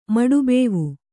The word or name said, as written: ಮಡುಬೇವು